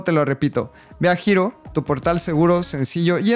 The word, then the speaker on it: Hiro: Para probar Giro, puedes ir a registrarte al waitlist y ser de las primeras personas en tener acceso. Así que puedes ir a giro.cool. Giro se escribe H-I-R-O.